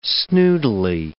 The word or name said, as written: snootily